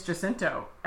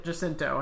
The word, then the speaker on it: Jacinto: It's Jacinto. And the reason I know that is because I watched a video today called Manny Jacinto Reads Thirst Tweets About Himself from BuzzFeed. Oh, dear. And so he pronounced it Jacinto.